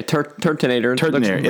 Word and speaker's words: Turtonator: Turtonator. – Yeah, Turtonator. – It looks like it's dynamite. The Nator part is detonator, and it's a turtle.